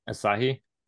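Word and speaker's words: açaí: I think it's Akashi, or how do you say it again? – Asahi. – Asahi.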